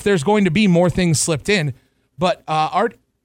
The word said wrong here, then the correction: Ardian: I keep mispronouncing his name. – I think it's Ardian.